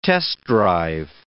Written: test-drive

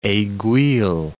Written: aiguille

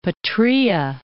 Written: Arabian Peninsula

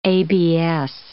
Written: ABS

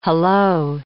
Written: hallo

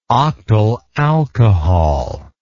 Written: octyl alcohol

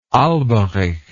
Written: alberich

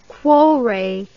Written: cuore

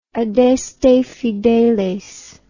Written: adeste fideles

